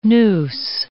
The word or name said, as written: nous